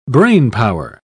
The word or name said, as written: brainpower